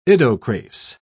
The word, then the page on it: idocrase: British English